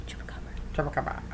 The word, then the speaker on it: chupacabra: Did I say that right? Chupacabra? Yeah. Okay. – Chupacabra.